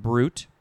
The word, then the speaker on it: bruit: Okay, now we have the second form of this same word, but in this case it's just pronounced brute, B-R-U-T, although it's spelled B-R-U-I-T.